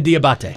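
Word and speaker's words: Diabaté: Yeah. Mohamed Diabate. – Yeah. – Yes. – Diabate.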